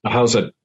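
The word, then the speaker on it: Ahousaht: Am I pronouncing that right? – Ahusat, yeah.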